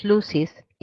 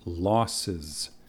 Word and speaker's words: losses: We must put up with less serious losses in order to avoid worse ones. – Anna, the word is pronounced losses. Losses.